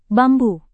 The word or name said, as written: bambu